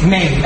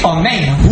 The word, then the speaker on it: Amen: Amen, I don't want to tell you what the Gemara says if you say Maine. But it says very, very, very bad. So you make sure you say Amen.